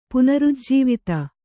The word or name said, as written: ಪುನರುಜ್ಜೀವಿತ